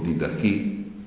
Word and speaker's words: Didache: Possiamo, la prima cosa è leggerla naturalmente, altrimenti proviamo a leggere questo testo, si chiama Didache dalla pronuncia moderna del greco Didache, cioè dottrina, sarebbe il testo completo, la dottrina dei 12 apostoli, cioè è tramandata così.